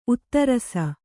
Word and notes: ಉತ್ತರಸ: uttarasa

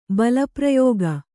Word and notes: ಬಲಪ್ರಯೋಗ: bala prayōga

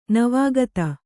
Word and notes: ನವಾಗತ: Kannada

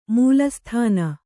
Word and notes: ಮೂಲಸ್ಥಾನ: mūla sthāna; Kannada

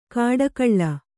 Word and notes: ಕಾಡಕಳ್ಳ: kāḍakaḷḷa; Kannada

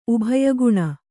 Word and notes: ಉಭಯಗುಣ: kannada

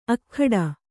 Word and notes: ಅಗ್ಗಡ: aggaḍa

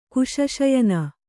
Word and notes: ಕುಶಶಯನ: Kannada

